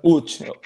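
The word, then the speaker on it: Łódź: Es el, el Lodz, ¿no? ¿Se llama el equipo? – Sí, el, el Uch.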